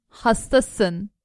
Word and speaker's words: hastasın: Justin, sen hastasın. – So that's how you say it. You are sick. Hasta. Sin.